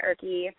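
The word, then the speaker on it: Ihrke: So I'm on Twitter at Shannon Erke. My last name is spelled awkward. It's I-H-R-K-E.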